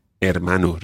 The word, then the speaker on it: hermano: Hermano. Remember the silent H.